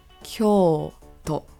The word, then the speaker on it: Kyoto: You should also be careful about this city's pronunciation because the O here is elongated in the first O but is very short in the second O. So for example, Kyoto.